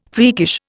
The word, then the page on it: freakish: Indian